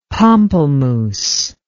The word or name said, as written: pompelmous